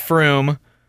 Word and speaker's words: Frome: Apparently Frome is spelled, is pronounced Froome. That's stupid. Okay, here's one. D-O-N-A-G-H-A-D-E-E.